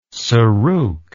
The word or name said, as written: sarouk